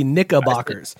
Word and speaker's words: Knickerbockers: I think you said Knickerbocker. There's a hard R in there. It's got to be Knickerbockers. You got to be careful. – Pass. – Wait. The Knickerbockers, don't they play in Massachusetts? – I bet you they do.